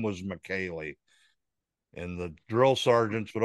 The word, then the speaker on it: Michele: It's spelt Michelle, but it's pronounced McKaylee.